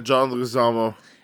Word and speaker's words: Leguizamo: How do you say his last name? Leguizamo? – Yeah, John Leguizamo.